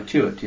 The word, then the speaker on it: Tuit: Wally, W-A-L-L-Y. – Yeah. – T-U-I-T, I think. – Oh, Chewett, yes. T-U-I-T.